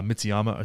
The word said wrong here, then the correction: Matsuyama: or is that how you say his name? Matsuyama.